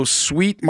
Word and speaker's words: Swheat: It's called Sweet Market Deli, and it's spelled S-W-H-E-A-T, like a chaff of wheat. So Sweet Market Deli, I've heard it's good, and there's a beautiful picture of a Reuben sandwich right on the website.